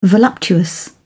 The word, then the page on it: voluptuous: British English